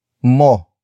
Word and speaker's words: mo: The next word is mo mo mo mo is spelled as m o